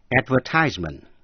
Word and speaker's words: advertisement: Và quý vị sẽ nghe chữ advertisement, đánh vần A-D-V-E-R-T-I-S-E-M-E-N-T, advertisement, là quảng cáo. Chữ advertisement có hai cách phát âm. Advertisement, đọc theo lối Mỹ. Advertisement, đọc theo lối Anh.